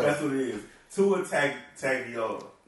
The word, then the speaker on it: Tua Tagovailoa: Hey, Adam Schefter, it is Tua Tagovailoa. Tua Tagovailoa. That's how you say it.